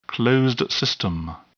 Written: closed system

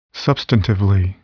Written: substantively

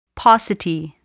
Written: paucity

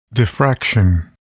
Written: diffraction

